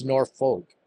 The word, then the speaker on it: Norfolk: I hope I said that right, because I get a lot of heat about my Boston accent. And I tend to add an extra R at the end, but it's Norfolk, Virginia, not Norfolk.